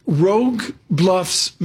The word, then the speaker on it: Rogue Bluffs: And Dan, we got a question here that's absolutely brilliant from Rogue Bluffs, Maine. I hope I'm pronouncing that right. – I think you got it.